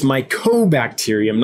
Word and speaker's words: mycobacterium: Like last week, I went on a whole rant about microbacterium and realized the next day when Michael Wentworth brought it to my attention that I've been saying it wrong, that it's mycobacterium, not microbacterium.